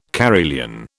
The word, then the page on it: carillon: British English